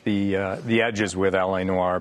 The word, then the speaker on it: L.A. Noire: But there's this L.A. Noire okay? And, and I hope I'm pronouncing it right. L.A. Noire, right. L.A. Noire. Yeah.